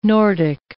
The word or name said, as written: Nordic